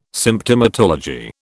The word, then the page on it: Symptomatology: British English